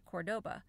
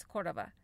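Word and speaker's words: Córdoba: So we were talking about how to pronounce Cordoba, because Americans, like, well, I could just say that most Americans would probably pronounce it Cordoba. But you got to give it a little flair. So we sat there and kind of talked about the pronunciation, and so it was like Cordova, Cordova, Cordova, Cordova. And so basically, I think the way you say it, I've been just practicing, but I think it's Cordova.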